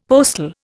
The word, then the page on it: postil: Indian